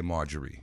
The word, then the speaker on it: Maggiore: In Italian, we'd say Maggiore, but you pronounce it a little different, right? – Yes.